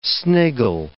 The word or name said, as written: sniggle